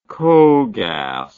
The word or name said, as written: cogas